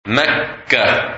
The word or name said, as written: Mecca